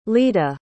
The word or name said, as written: lida